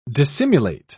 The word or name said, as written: dissimulate